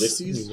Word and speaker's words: Ulysses: Is that how you say it? – Ulysses. – Yes. Ulysses. – Ulysses.